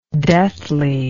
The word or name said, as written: deathliness